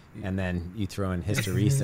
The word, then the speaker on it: hysteresis: Hysteresis.